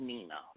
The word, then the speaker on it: Nina: Nina. – It could be. – You know what I mean? Am I saying that right? – Yeah, you're saying it right because you said that perfectly well.